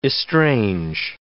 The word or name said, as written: estrange